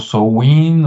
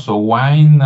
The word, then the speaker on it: Samhain: Pero un poquito antes, había un nombre, déjenme buscarlo aquí en un acordeón, que se escribe de una manera, pero se pronuncia de otra. Se escribe Samhain, así como Sam de Samuel y luego H-A-I-N, Samhain. Y, pero dicen que se pronuncia muy diferente, como que no viene del inglés inglés, ¿no? Como que son de las cosas allá de los celtas y, se pronuncia como so-win o so-wine, algo así, y luego de ahí se, deriva la palabra que decía Ricardo y luego Halloween del, del, Win, ¿no?